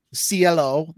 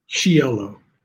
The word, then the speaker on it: Cielo: And I call it Cielo. – Cielo. That's beautiful. – Cielo. Yeah. That's even more Italian.